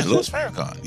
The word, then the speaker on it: Louis Farrakhan: Yeah, Louis Farrakhan.